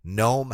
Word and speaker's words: Gnome: Gnome Hammer Forge. The G is silent.